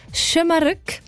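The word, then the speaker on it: Şımarık: Also man sagt «Schömerück».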